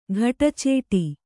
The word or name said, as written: ಘಟಚೇಟಿ